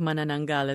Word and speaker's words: Manananggal: And I got to say, your pronunciation of Manananggal is quite impressive.